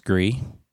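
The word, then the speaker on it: Gris: So I got Grist over the Christmas break and played that pretty much the entirety of Christmas Day. It is now pronounced Gree.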